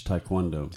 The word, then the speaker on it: Taekwondo: So you're big into you teach Taekwondo. – Yes. – Am I saying that right? – Correct.